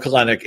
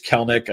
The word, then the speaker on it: Kelenic: It's not – everyone wants to call him Kelenic. It's Kelenic. I know it doesn't make sense the way it's spelled, but you want pieces of the Atlanta offense, and he's the cheapest way in.